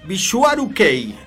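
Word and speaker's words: Visuaru Kei (ヴィジュアル系): Les contaba esto del Visual Kei, que se pronuncia en japonés Visuaru Kei,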